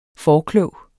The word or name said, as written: forklog